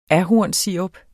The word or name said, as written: ahornsirup